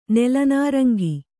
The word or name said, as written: ನೆಲನಾರಂಗಿ